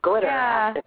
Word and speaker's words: Glitteractica: It's just like Galactica, as in Battlestar, but Glitteractica.